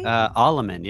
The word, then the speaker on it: Allemann: Allemann.